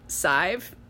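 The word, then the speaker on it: Sadhbh: Her name is spelled S-A-D, B-H, H-B-H, S-A-D-H-B-H. Pronounced Sive. I had never seen this word before, but I read it as sad hubba.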